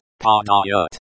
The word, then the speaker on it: Падают: The sound at the end of this word is like the in father. It is pronounced padayut.